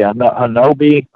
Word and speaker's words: Honobia: Hanobi, Oklahoma, it's spelled H-O-N-O-B-I-A. And okay. Some people say Hanobia.